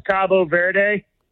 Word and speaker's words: Cabo Verde: Either way, you said it right. That's the it's kind of like Turkey, Turquia. – Yeah. – They in Spanish, it's Cabo Verde. – Cabo Verde. – Cabo San Lucas or whatever in Mexico. Cabo Verde. But okay. The English pronunciation is Cape Verde.